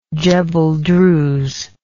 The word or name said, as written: Djebel Druze